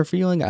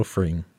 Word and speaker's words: Fring: Gus Fring, I think it is, or Freeling. I don't know how to say it. – Gustavo Fring.